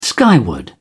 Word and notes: skyward: British English

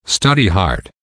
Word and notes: study hard: American English

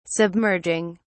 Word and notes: submerging: British English

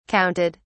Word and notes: counted: British English

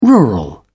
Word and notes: rural: British English

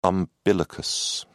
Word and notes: umbilicus: British English

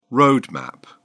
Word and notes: road map: British English

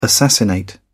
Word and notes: assassinate: British English

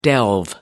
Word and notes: delve: American English

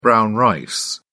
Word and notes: brown rice: British English

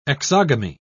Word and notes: exogamy: British English

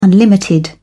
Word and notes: unlimited: British English

